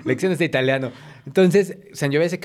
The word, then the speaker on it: Sangiovese: Y ojo, se pronuncia Sangiovese.